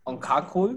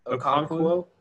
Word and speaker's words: Okonkwo: And then Chig Okonkwo, am I saying that right? – Okonkwo.